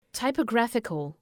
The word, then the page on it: typographical: ,taıpə’græfıkəl